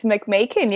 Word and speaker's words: Macmeikan: Today, I have the privilege of speaking to Dr. Joanna, I'm going to say your surname wrong, I'm sure, McMahon? – It's McMahon. You were pretty close.